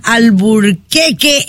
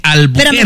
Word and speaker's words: Albuquerque: Hoy entramos en una estación nueva allá en Albuquerque, en Nuevo México. Lo digo mal siempre, ¿verdad? – Sí, patrona, pero se le quiere, es Albuquerque.